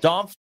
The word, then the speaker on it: Dampf: Dampf beer.